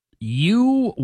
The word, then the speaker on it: Uyghur: Uyghurs. If you are reading about that, probably fair to assume you would not know it's pronounced Uyghur.